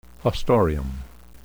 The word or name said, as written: haustorium